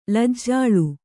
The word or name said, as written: ಲಜ್ಜಾಳು